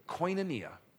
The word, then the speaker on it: koinonia: It's pronounced koinonia.